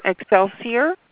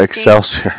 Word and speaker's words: Excelsior: Excelsior. – Marking. Oh, Excelsior.